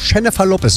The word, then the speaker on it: Jennifer Lopez: Jennifer Lopez, so spricht sich das aus. Jennifer mit dem weichen Jennifer Lopez und David Guetta.